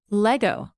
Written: lego

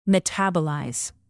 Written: metabolize

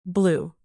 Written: blue cheese

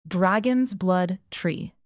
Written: dragons blood tree

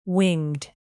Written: winged

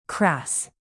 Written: crass

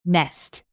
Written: nest

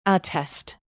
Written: attest

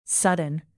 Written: sudden

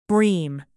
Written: bream

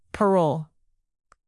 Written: parole

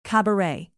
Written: cabaret

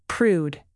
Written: prude

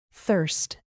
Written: thirst

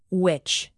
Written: witch

